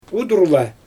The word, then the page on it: udurla: Xyzyl